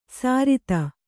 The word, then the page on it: ಸರಿತ: sarita